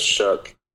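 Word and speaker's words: Shough: Is it Shuck or Shug? It's Shuck, right? – It's Shuck. – Yeah, like aw shucks.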